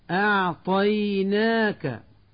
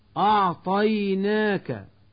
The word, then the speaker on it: أعطيناك: أعطيناك، ويقول من يخطئ أعطيناك بتفخيم الهمزة وتفخيم الياء، الكوثر، ويقول من يخطئ الكوثر بتفخيم الكاف والثاء.